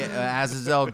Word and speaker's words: Azazel: Azazel. Is that how it is? – That's what it says on here. – Can you spell it for me? – Azazel. – Yeah, that's Azazel, all right.